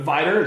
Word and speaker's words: Vidor: Yeah, people pronounce it Vidor. Yeah, but Vidor, it's a little big, small town right outside of Beaumont, southeast Texas.